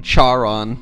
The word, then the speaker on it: Charon: Sharon is spelled with a C, not an S, so it's not just some lady named Sharon. – Charon. – Yeah, Charon, I guess, is the proper way to say it.